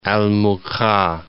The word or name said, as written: Al Mukha